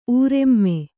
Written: ಊರ್ಮೆ

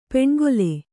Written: ಪಣ್ಗೊಲೆ

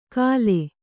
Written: ಕಲೆ

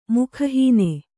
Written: ಮುಖಹೀನ

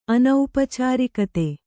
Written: ಅನೌಪಚಾರಿಕತೆ

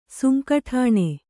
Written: ಸುಂಕಠಾಣೆ

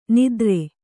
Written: ನಾದಾರ

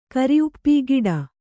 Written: ಕರಿಉಪ್ಪಿ ಗಿಡ

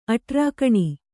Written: ಅಠಾರಖಾನೆ

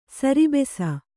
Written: ಸರಭಸ

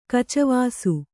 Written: ಕಚವಾಸು

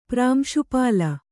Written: ಪ್ರಾಂಶುಪಾಲ

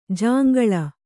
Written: ಜೊಂಗುಳಿ

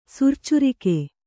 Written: ಸುರ್ಚುರಿಕೆ

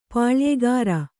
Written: ಪುಲಿಯುಗುರ್

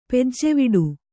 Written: ಪೂಜೆವಡೆ

